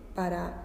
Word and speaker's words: pas: palabra que es PAS, pa no se pronuncia la S.